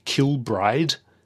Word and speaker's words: Kilbride: Isn't the stress on the last syllable as well? Isn't it East Kilbride? – Maybe.